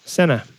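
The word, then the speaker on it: Sena: Do you pronounce it Senna or Senna? – Senna. – Senna, okay. It's I've been saying things, it Senna forever. – Yeah, it's one of those things that a lot of people get wrong, apparently.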